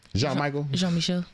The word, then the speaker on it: Jean-Michel: And the reason why I'm agreeing is because, like, I don't think Michael Jean Basquiat created, I say his name right? – Jean-Michel. Yeah.